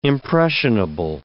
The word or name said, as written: impressionable